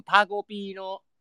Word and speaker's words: タコピー: いや、そう、なんか昨日さ、上田玲奈ってさ、トレンドに入ってたからさ、何かなと思ったんだけどさ、なんか、でもなんか俺でもさすがになんかツイッターのトレンドかなんかに上がってないと、タコピーの現在。あれでしょ、なんかカキピーと同じイントネーションだよ、多分。